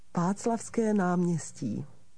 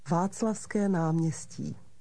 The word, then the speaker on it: Václavské náměstí: How do you say Wenceslas Square in Czech? – Václavské náměstí. Václavské náměstí.